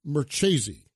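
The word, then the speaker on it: Marchese: Tom Merchese. I believe it is, okay? He's Sicilian, so I think the E on the end is pronounced, okay?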